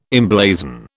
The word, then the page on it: emblazon: British English